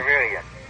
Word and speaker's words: Sansevieria: Sansevieria. – What is it? – Sansevieria. – Sansevieria? – S-A-N-S-E-V-I-R-E-A.